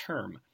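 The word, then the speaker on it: Herm Edwards: Did you say Irm Edwards without an H? – Maybe I did. – I'm pretty sure you did. – I've never heard that pronunciation. I believe it's Herm.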